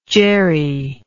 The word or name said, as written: jeri